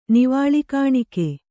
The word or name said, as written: ನಿವಾಳಿಕಾಣಿಕೆ